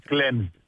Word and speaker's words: Cleansed: Να πούμε λοιπόν για την παράσταση της, το έργο της Σάρα Κέιν, το Cleansed, αν το προφέρω σωστά, που παίζεται... – Ναι, λέγεται Cleansed.